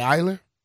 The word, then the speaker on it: Eyler: How would you pronounce E-Y-L-E-R? – Probably Eiler.